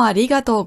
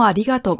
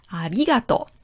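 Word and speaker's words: Arigato (ありがとう): Domo arigato gozaimas. Domo arigato gozaimasu. Ah ja, Betonung. Arigato. Beim I. Arigato. Nicht arigato. Ja, nicht auf dem To hinten, sondern arigato.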